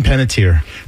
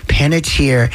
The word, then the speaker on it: Panettiere: Hayden Panettiere. – Panettiere.